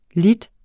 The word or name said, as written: lidt